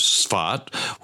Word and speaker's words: Sfat: Now, some would say it would have been Sfat, Safed, Safed. It's pronounced all different ways. In Hebrew, the word is Sfat, which is a city up on the western hills behind Capernaum. But the fact is, when you're in Capernaum, you would not be looking to the west.